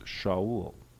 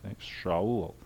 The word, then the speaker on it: Shaul: His name will be pronounced Shaul, okay, with an H in there, Shaul.